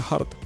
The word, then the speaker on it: Hurt: ¿Cómo se pronuncia Hurt? – Hurt. – Hurt es corazón. – Como John Hurt, claro.